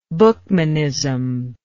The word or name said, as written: buchmanite